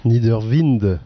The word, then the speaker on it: Niderwind: Je l'ai bien prononcé Niederwind. – Niederwind. À l'alsacienne.